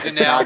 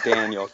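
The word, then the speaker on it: Dannell: It's Danelle. Not Daniel, goddamn it.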